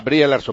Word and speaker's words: Brie Larson: Bueno, como se diga, Brian Larson, me parece que se dice así, Brian Larson, ¿no? – Sí. – Bueno, pues Brie Larson se escribe. – Brie Larson, pues esa, pues Brie Larson.